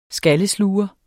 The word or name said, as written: skallesluger